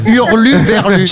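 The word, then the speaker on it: hurluberlu: Mais j'ai jamais su prononcer Uber Lulu. Uber Lulu, c'est une pute qui travaille dans les bureaux. – Qui te dépose en bas chez toi. – C'est Hurlu Berlu.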